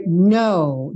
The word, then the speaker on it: know: More than you could ever know. Know, o K é mudo, tá?